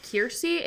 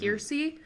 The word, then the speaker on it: Keirsey: And again, if you want to take this, it's called Kiersey. It's spelled K-E-I-R-S-E-Y, mm-hmm. Kiersey.